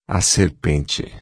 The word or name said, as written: serpente